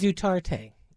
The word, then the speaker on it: Duterte: This one is Duterte.